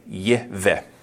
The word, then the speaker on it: Yahweh: You would pronounce his name Yahweh. That's how God's name is actually pronounced if you were to look at it in Hebrew, Yahweh.